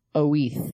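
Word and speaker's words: Oiz: They said, to put things in context, here's a quick history of the Wheeze model, which Orbea, being a Basque company in Spain, it's pronounced Oeth.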